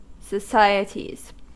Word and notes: societies: American English

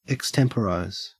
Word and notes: extemporise: Australian English

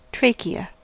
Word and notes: trachea: American English

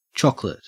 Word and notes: chocolate: Australian English

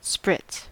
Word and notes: spritz: American English

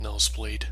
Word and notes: nosebleed: American English

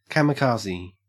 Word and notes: kamikaze: Australian English

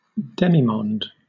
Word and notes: demimonde: British English

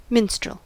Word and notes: minstrel: American English

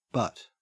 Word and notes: but: Australian English

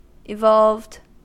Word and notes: evolved: American English

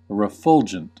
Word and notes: refulgent: American English

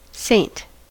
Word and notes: saint: American English

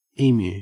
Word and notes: emu: Australian English

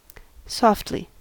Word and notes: softly: American English

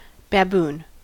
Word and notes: baboon: American English

